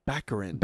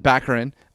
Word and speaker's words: Baccarin: No, Morena Baccarin, I couldn't be there for. – We have to say it right. It's Baccarin. – Baccarin.